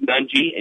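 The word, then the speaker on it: Nnaji: Nunge. Is it Nunge or Nunge? I can't remember. How do you pronounce it? – I say Nunji. – No, I'm going with Nunji, and you know what? – I've always said it.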